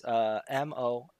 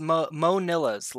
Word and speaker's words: Monillas: Monillas. – I believe you told us to pronounce it Mo-nil-ass. – No, it's Monillas. Like you want more of those little wafers. – Okay. – Monillas.